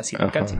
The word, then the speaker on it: Tzilacatzin: Empieza con T, Tzilacatzin, pero se pronuncia Tzilacatzin.